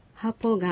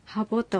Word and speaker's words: ハポ: サマニ方言でも例えばハポがハボと濁って発音されることもままあります。